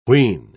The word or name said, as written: wheen